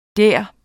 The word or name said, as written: der